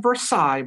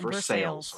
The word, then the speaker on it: Versailles: Looks like Versailles, but it's pronounced Versailles.